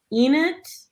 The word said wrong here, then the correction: Ennead: like whatever the nine gods. – Oh, the Ennead.